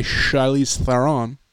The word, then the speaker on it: Charlize Theron: And they're kind of like a superhero squad led by Charlize Theron. Ooh. I believe is the proper pronunciation.